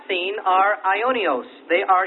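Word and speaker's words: Ionios (αἰώνιος): Now, the Greek term used in the New Testament to denote eternity is used in 2 Corinthians 4:18, and it's pronounced Ionios. It's spelled A-I-O, and there's a long bar over that O, which is a typical long bar in English, as in hope. And then N-I-O-S, Ionios, 2 Corinthians 4:18.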